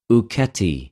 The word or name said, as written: οὐκετι